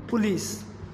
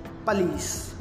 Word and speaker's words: police: Vegetable, V-E-G-E-T-A-B-L-E, vegetable, which is correctly pronounced as vegetable, vegetable. Police, police, P-O-L-I-C-E, which is correctly pronounced as police, police, not poo, it's pa, police. Restaurant, R-E-S-T-A-U-R-A-N-T, restaurant, which is correctly pronounced as restaurant, restaurant. Determine, D-E-T-E-R-M-I-N-E, determine, which is correctly pronounced as determine, determine.